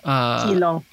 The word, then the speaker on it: ilong: All right, so number four, we have nose, and in Tagalog, it's ilong. – Ilong, mm-hmm. – Oh, okay, so ilong. – Ilong. – Or is it ilong? So you emphasize the first syllable? – Yeah.